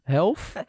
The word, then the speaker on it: health: Ik moet dit altijd heel goed uitspreken, maar ik vind het altijd heel moeilijk. Mental health. Health? – Health. – Health. Health. – TH.